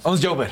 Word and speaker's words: Ons Jaber: Ons Jaber Ons Javer.